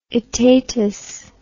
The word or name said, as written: aetatis